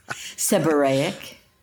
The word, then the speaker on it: seborrheic: How do you pronounce Seborrheic? – Seborrheic.